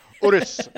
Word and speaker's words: Urus: I did the video of it, I had to keep going over to the PR person who said Urus. Urus. Urus. Urus. And I might be saying it wrong again. Who knows? I just kept walking over to her and be like, "Wait." Urus or Urus? She's like, Urus.